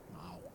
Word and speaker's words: Mewk: It's like M-E-W-K. – Wait. – Mock.